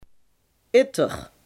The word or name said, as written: Neeritter